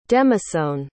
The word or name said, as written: demasone